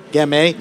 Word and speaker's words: Gemme: Is that how you say it, Gemme? – Gemme. Yep.